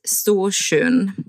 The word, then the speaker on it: Storsjön: Äh, und der See heißt Storsjön.